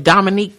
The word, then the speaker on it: Dominique: They can find me at Dominique Comedie, C-O-M-E-D-I. My first name is spelled D-O-M-I-N-I-Q-U-E.